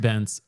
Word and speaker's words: Bence: Richard Bence, pronounced like fence. – Not bonk. Not bank. – Not bonk, yeah. Or bank.